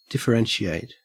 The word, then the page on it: differentiate: Australian English